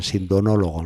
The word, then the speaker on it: sindonólogo: Y el padre Rafael es un gran sindonólogo, ¿lo he dicho bien? Sí, eh, pues así es.